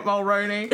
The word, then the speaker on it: Mulroney: Sexy ass Dermot Mulroney. – Is that even how you pronounce his last name? – Yeah.